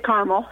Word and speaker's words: Carmel: Is it Carmel or Carmel? How do you pronounce it? – It's Carmel. – Carmel, okay.